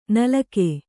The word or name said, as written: ನಾಲಿಗೆ